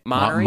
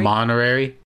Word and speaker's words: Monterey: It's always something in California, but it's Mac OS Monterey. – Monterey? – Monterey. Monterey. Monterey. – Yeah, that sounds about right.